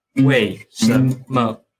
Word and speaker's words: 为什么: And then the neutral tone here, if you know how to pronounce machine, then it's quite easy, just like machine, machine. You know, notice that, you know, the machine, the is kind of fast. Machine, it's like So if I pronounce three words like together, it's like 为什么, 为什么, 为什么, 为什么.